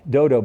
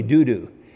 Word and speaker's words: Dodo: He's of the tribe of Issachar, and he's grandson, I used to say, because I didn't like to pronounce the word, I used to say Dodo. That's what it looks like, Dodo, but it's really Dudu.